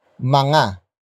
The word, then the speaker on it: mga: But before that we have mga. Mga. Mga. Mga is spelled as M-G-A.